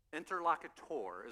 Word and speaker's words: interlocutor: Interlocutor is the way you pronounce it.